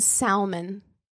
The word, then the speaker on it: salmon: He pronounced the L in salmon.